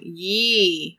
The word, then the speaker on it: Ye: You said it wrong. – I said it wrong. Yee, that guy is interesting.